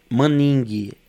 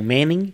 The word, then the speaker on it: Maningue: Maningue é mudo, né? Com G mudo. Não é UE. As pessoas leem geralmente como Manning, que é a influência do inglês,